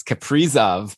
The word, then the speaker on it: Kaprizov: Brian, we've got tweets and Ben even called us out for saying that name wrong. It's Kaprizov.